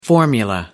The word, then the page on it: formula: American English